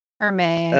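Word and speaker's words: Hermès: Hermes.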